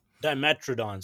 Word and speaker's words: Dimetrodon: Because another thing I'll be frivolously spending my money on, my hard earned money, will be the Grant and Dimetrodon. Am I saying that right? – Dimetrodon.